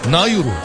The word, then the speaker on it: Nauru: Pasifik ada ülkesi Nauru Cumhuriyeti. Nauru. Nauru'ymuş, öyle okunuyormuş.